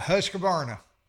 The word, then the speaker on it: Husqvarna: Yeah, Husqvarna. – Yes, sir. That's it.